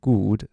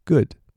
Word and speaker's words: good: 所以注意不要念成 good，是 good。